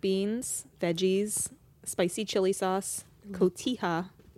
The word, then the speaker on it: cotija: cotija cheese. – You mean cotija. – Oh, I always say that wrong.